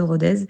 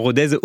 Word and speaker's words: Rodez: Effectivement, Rodez ou Rodez. Moi-même, j'ai hésité, j'ai dû demander à une amie du coin, mais elle m'a dit que n'habitant pas Rodez, je pouvais me permettre de prononcer le Z.